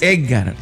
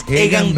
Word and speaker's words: Egan: No, es que no es Edgar. No, de pronunciar. Edgar. No es Edgar. – Es Egan Bernal.